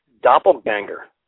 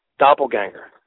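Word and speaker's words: Doppelgänger: It is doppelganger. That's right, doppelganger.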